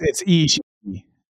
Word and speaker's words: Ishii: Yeah, it's Ishii. It's like the one, you kind of like double say it.